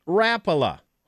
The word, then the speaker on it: Rapala: Actually, Rapala is not the correct pronunciation. It's actually Rapala.